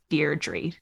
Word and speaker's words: Deirdre: I like Dear Deirdre better. Did I say it right? – You did. – Nailed it.